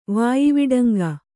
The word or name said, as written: ವಾಯಿವಡಂಗ